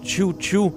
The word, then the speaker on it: Xiu Xiu: Avant Dull Drums, nous écoutions le groupe Chiu Chiu, qui s'écrit X-I-U-X-I-U, donc Chiu Chiu, et la pièce Stupid in the Dark, qui se trouve sur l'album Angel Guts Red Classroom, sorti en 2014.